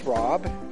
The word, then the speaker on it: Rob: N-O-N-A. – And Nona, where are you from? – Kentucky. – My name is Rob. – Rob, can you spell that? – R-O-B.